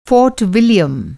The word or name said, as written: फ़ोर्ट विलियम